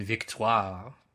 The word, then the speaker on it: Victoire: They have three children, Louis, Dominique, and Victoire. Because you definitely have to pronounce it that way.